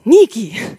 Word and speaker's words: Nikki: That's how you say my name in Portuguese. Nikki. Just a little bit more passion, you know, than in English, Nikki.